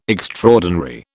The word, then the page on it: extraordinary: British English